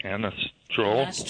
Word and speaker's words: anastrozole: So I'm taking 1/4 milligram, probably won't pronounce this correctly, Anastrozole.